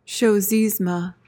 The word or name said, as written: chosisme